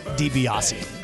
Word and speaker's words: DiBiase: Tobias. – He was the million-dollar man in the WWF. – Did I say it right? – No. – Oh, well, what is it? – Ted DiBiase.